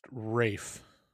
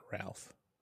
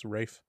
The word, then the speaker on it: Ralph: I believe it's pronounced Rafe, not Ralph. You uncultured swine. – Is it really? – Mm-hmm. – Why would you spell it R-A-L-P-H? – Because it's the English way of pronouncing it. It's Rafe.